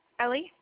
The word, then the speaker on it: Ellie: Ellie. – You said Ellie? – Yep. – Okay, how you spell that? – E-L-L-I-E.